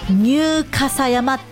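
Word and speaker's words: 入笠山: どんな漢字書くかというと、入るという字と、あの竹冠に立つと書いて傘、そして山、ニューカサ山って読むんです。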